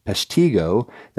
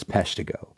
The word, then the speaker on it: Peshtigo: Now, you may hear or things such as Peshtigo, that's really not the correct pronunciation, it's Peshtigo, which lies along the Peshtigo River, estimating a death toll, now listen to this, estimating a death toll at 1,152.